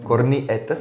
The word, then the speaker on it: korniet: Korniet? – Nenávidím, jak to říkají lidi, korniet nebo korniet, kornet nebo jak oni to říkají. Korniet, ano. Čte se to by s tvrdým, ale je to s měkkým.